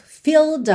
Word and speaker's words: filled: Aqui é super importante você realmente interiorizar, por exemplo, que filled, você não pronuncia a letra E, porque senão não dá nem tempo, se você for tentar falar filled up, não dá tempo. Esquece essa letra E, tá pintadinha de cinza, ela não é pronunciada, então você pensa em filled.